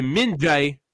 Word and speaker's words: Min-jae: Puis c'est Min-jae, c'est la bonne prononciation pour le défenseur coréen, si je ne m'abuse.